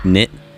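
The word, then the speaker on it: Gnit: Like a couple songs ago, we had Knit by Autechre. That's G-N-I-T. I'm pronouncing it Knit because that seems right.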